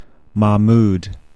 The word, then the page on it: Mahmoud: mæħˈmuːd